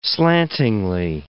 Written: slantingly

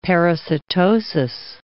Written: parasitosis

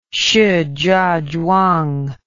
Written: shijiazhuang

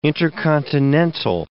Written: intercontinental

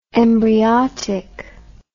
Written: embryotic

